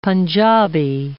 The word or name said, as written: Panjabi